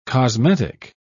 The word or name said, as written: cosmetic